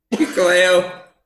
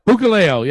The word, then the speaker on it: Hikuleo: But going back to, how do you pronounce his name? – Hikuleo. – Hikuleo, yeah.